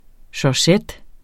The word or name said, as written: georgette